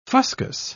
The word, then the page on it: fuscous: British English